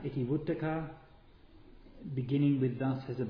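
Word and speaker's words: Itivuttaka: I'll just say, go back to the Iti Yukta is the Sanskrit pronunciation. In Pali is Iti Vuttaka.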